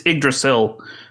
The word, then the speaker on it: Yggdrasil: Yggdrasil is, I believe, the most common way to say it. I know English speakers typically say Yggdrasil, but it's Yggdrasil in the old Nordic way. – Silent G's, right? – Pretty much.